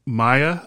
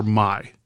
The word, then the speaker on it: Mai: Maya or Mai? – Mai. Mai. – Mai. – Mai. Sakurajima. Okay. it's uh, actually pronounced Mai. Mai, like that.